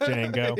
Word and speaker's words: Django: Like when they talked about Django and that he was going to get, I love calling it Django because the D is silent. It's Django.